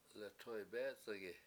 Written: letoybEEc71gi

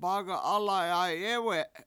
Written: baga7Alay7ayEwe7

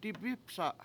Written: dibIpsa7

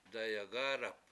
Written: dayagAAdap

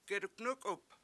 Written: geduNUkup

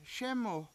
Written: SEmu

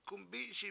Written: gumbISi7

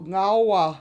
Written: ngAwa

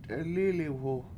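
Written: t'elí:liwhu

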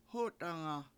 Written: hOtanga

